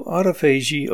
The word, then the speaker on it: autophagy: Auto is like a car. Phagy is pronounced phagy. You can probably even figure out by the definition, as it sounds like self-phagy, which is self-eating. So autophagy on the cellular level would be the cell eating itself.